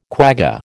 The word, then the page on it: quagga: American English